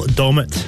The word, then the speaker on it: Dommett: Is it Joel Dunnett? Is that his name? – How do you say? Is it Dommett? – So are you both looking at me here? – Yeah. – Joel Dommett.